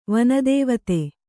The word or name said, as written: ವನದೇವತೆ